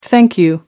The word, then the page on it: thank you: American English